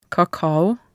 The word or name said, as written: kakao